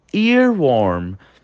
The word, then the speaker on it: earworm: Vamos lá então para nossa próxima expressão, que é a expressão earworm. Earworm. É um pouco difícil essa pronúncia, né? A língua dobra um pouquinho.